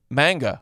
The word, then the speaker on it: manga: Who is who is a magna? Magna? Am I saying it right? Why do I feel like I'm not? – Magna cum laude? – Magna. – Manga. – Manga. – Manga. – Manga. – Oh, yeah. Manga.